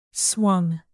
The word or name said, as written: swung